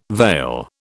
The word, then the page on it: veil: British English